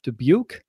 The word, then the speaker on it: Dubuque: He was a Division III standout from Dubuque. Yes, that's how you pronounce it.